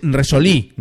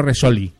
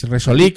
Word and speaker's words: Resolí: Me apunta María que es Resolí, no Resolí. Es Resolí con acento en la I.